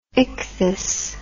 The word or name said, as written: ichthys